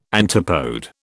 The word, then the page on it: antipode: British English